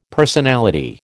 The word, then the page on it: personality: American English